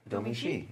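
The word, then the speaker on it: Domee Shi: I think it's Domi Shi. – Domi Shi, yeah.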